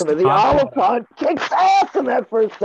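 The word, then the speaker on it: Olyphant: Yeah, Timothy Oliphant, if that's how you say his name.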